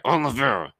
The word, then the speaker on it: Oliveira: And then I predicted Randy Brown over Oliveira. Say it right. Oliveira.